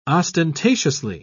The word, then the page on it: ostentatiously: British English